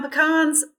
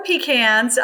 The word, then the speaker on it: pecan: I will say what my grandfather always said about how you pronounce P-E-C-A-N is they're pecans when you pick them and pecans when you sell them.